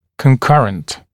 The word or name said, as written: concurrent